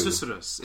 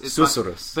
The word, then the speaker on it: susurrus: It's like you pronounce Susurrus. – Susurrus.